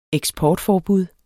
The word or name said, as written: eksportforbud